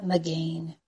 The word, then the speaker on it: Magen: But it all started with Deuteronomy 33:29, where he is called the Lord the shield, Jehovah Magen. It's spelled M-A-G-E-N.